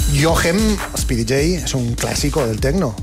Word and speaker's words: Speedy J: Speedy J. – Speedy J, ¿no? Yo siempre he dicho Speedy G y es Speedy J, claro, porque es una J, no una.